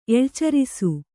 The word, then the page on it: ಎಳ್ಚರಿಸು: eḷcarisu; Kannada